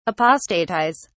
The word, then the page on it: apostatise: British English